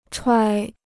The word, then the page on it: 揣: chuǎi